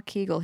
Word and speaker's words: Kegel: This is about Kegel balls. I don't know if I pronounced that correctly. Or called Benoit balls. – Mm-hmm. – So them geisha balls, kind of, in Swedish. – No, we call them Kegel here, too.